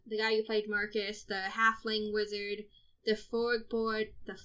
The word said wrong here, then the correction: Furbolg: that's not how you say it that way, Furbolg? – Furborg. I don't think I could say the race name. – The big forest people, the Furbolgs? – Yes.